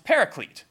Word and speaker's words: paraclete: So the actual word in Greek is basically pronounced paraclete.